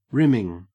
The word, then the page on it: rimming: Australian English